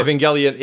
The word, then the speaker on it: Evangelion: I do remember being introduced to evangelion i think it's a hard g uh, yeah. – evangelion